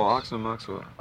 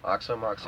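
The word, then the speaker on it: Aoxomoxoa: Oh, Oxomoxa. – Oxomoxa. – Oxomoxa.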